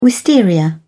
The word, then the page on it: wisteria: British English